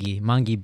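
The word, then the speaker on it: Mangue: We're going to start off with Manji Beat, which was a genre, and this was considered Manji. Am I saying it right? – Mangi Beat.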